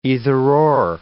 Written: either-or

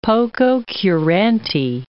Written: pococurante

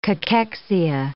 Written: cachexia

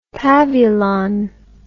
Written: pavulon

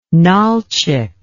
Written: Nalchik

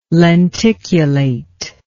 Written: lenticulate

